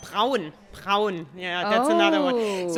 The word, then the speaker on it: Braun: So we really pronounce the R. Like Braun. – Okay. – Can you do that? Braun. – Braun. No, I created a new syllable. – No, it's perfect. – Okay. – Braun. Lots of people have difficulties with the German R.